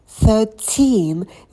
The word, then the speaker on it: thirteen: Thirteen. Здесь ударение идет на второй слог.